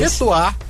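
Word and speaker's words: Chez toi: Chetoá_ em seu lugar. Chetoá se escreve CHEZ, e Toá se escreve TOI.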